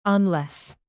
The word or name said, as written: unless